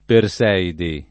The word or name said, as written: Perseidi